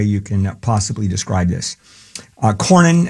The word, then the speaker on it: Cornyn: Cornyn. – John Cornyn? – Yeah, Cornyn.